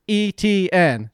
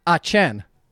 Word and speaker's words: Etienne: How you pronounce his last name is just like the letters E-T-N. Etienne. – That's dumb. – And hold on, it's not even French. It's actually technically Cajun Louisiana, which I know is French descent, but... It's actually, if you pronounced it from there, it would actually be Achen.